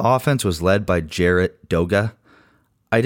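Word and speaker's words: Doege: His name is pronounced Doga, like toga.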